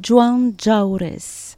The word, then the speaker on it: Joan Jaurès: C'est laquelle alors, j'aime beaucoup prononcer euh, Jean Jaurès en occitan. C'est Joan Jaurès ça claque bien avec les jeux, les, la diphtongue ao, jaures et juan.